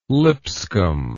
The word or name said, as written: Lipscomb